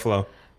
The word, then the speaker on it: Ruffalo: Mark Ruffalo. – Ruffalo.